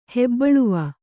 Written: ಹೆಬ್ಬಳುವ

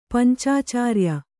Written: ಪಂಚಾಚಾರ್ಯ

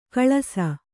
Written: ಖುಲಾಸು

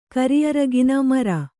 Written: ಮೆರೆ

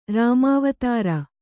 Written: ರಾಮಾವತಾರ

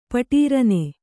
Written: ಪಟೀರನೆ

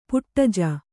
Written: ಪೊಡೆಜ

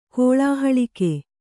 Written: ಕೋಳಾಹಳಿಕೆ

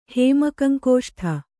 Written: ಹೇಮಕಂಕುಷ್ಠ

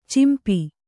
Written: ಚಂಪೂ

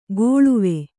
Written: ಕೊಳುವೆ

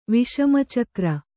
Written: ವಿಷಮಚಕ್ರ

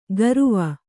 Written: ಗರುವ

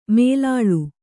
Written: ಮೇಲಾಳ್

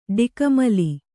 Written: ಡಿಕ್ಕಿಮಾಲೆ

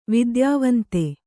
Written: ವಿದ್ಯಾವಂತೆ

